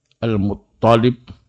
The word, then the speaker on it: Al-Muttalib: Nah, beliau berkata, telah menceritakan kepada kami Al-Muttalib ibnu Ziyad. Ini pakar hadis di zamannya, Al-Muttalib, ya, Al-Muttalib ibnu Ziyad. Toknya di tasdid, jadi bukan lamnya.